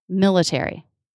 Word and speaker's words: military: Another example, military. Military. – Mm-hmm. – Mil is the stressed syllable. Ter. has secondary stress. So it's a true T.